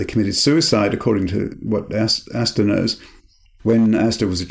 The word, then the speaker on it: Aster: The main character is someone called Aster, A-S-T-E-R. Would you pronounce it Aster? Aster? – Yeah, that's what I like.